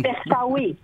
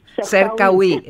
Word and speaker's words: Σερκαουί: Σύγχρονος χορός είναι, το προσωπικό βίωμα του Σίντι Λάρμπι Σερκάουι. Το είπα σωστά το όνομα, έτσι? – Σερκαουί. – Σερκαουί, εκεί τονίζετε, βέβαια.